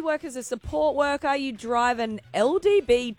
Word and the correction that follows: LDV: You're saying it right. LDV. – Oh, LDV. LDV.